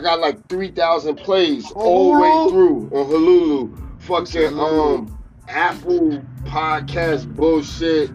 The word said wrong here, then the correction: Hulu: Is it Hulu or Hululu? – Nigga, that... My nigga, how do you say that shit? The green shit. The H-U-L-U shit. – Yep, Hulu.